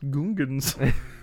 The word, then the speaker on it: Gungans: Oh, actually, it's pronounced Gungans.